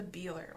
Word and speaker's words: Bieler: How would you pronounce it? – It's spelled like B I E L E R. It sounds. It looks like the Beeler or the Beeler family.